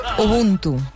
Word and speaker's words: Ubuntu: Se trata del musical Ubuntu Circus, que además, ¿lo he dicho bien? – Ubuntu. – Ah, he dicho Ubuntu.